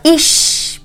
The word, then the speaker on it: ish: Ish rhymes with fish.